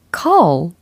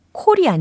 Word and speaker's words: call: call, call 이라는 단어입니다. 전화를 할 때도 call이라고 할 수 있지만 누군가를 부를 때도 call이라고 할 수가 있죠. call 이렇게 발음 연습을 해 두세요. 콜이 아닙니다. 콜 아니고 컬 아닙니다.